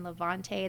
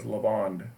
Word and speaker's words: Lavande: Those are two of my, I don't know if I'm, am I saying that right? Levante? That's how I always pronounce it in my mind. – It's always been pronounced, in my experience, as Lavande. L-A-V-A-N-D-E, Lavande. – Lavande. Oh, that's way better than Levante.